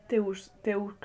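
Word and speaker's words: Teux Deux: Eh, todo, que se escribe teux, deux, como Todo en francés, que es una web. Teux teux, deux.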